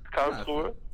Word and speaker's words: Karlsruhe: I believe you were born, and I'm going to get this pronunciation wrong, but Karlsruhe, Germany? – Yeah, that was not too bad. Karlsruhe. But, I mean, I know you got the Americans don't really speak the H, so it's understandable that it's Karlsruhe instead of Karlsruhe.